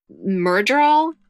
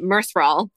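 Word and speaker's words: Myrddraal: My sister was rereading the Wheel of Time books, and one of the like baddies or beasties in that book are called Murdral. – Yeah. – But I was pronouncing it Mirthral because it is. – Oh, they do D. – have two D's.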